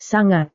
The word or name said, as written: sangat